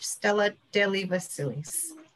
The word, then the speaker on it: Stella Delivasilis: Um, just my Facebook page, Stella Delivasilis.